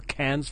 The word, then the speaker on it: Cannes: Be fun to see some independent film channel or Sundance or Cannes Film Festival. Did I say that right? I said Cannes. – Cannes. – Is it wrong or is it Cannes? Cannes. I think I had it right.